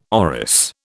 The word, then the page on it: orris: British English